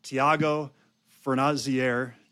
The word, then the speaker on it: Thiago Fornazier: And I have with me today a powerhouse newer agent, Thiago Fornazier.